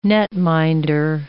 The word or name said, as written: netminder